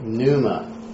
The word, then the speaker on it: pneuma: Yeah, I've heard it both ways, but since we don't have a vowel there, right, and it's one syllable, so it's like pneuma. You know, pneuma. But there's, if you do that, you've added like another syllable. So pneuma, I just say pneuma, pneumatos.